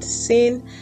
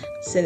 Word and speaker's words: Syn: Welcome to the Lagos to London podcast, and I'm your host, Syn. Syn is spelled S Y N.